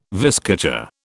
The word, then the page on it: viscacha: British English